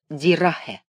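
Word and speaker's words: die Rache: По-немецки это слово произносится «дирахе».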